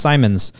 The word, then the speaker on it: Simmons: But when I said, "Oh, you're Vern Simmons," he's like, "Yeah, I am," but it's it's pronounced Simons. Interesting. And I was like, "Okay." I've never heard somebody uh, pronounce his name that way. Yeah, Simons.